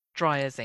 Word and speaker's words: Dreyer: And talking of someone who knows how to tell a good story, our final suggestion is Dreyer's English. Have I said that right? – Yes, it's Dreyer.